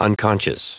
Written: unconscious

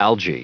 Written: algae

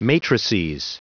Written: matrices

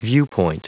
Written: viewpoint

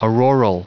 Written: auroral

